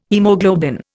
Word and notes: haemoglobin: Indian

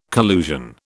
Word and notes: collusion: British English

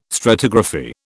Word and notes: stratigraphy: British English

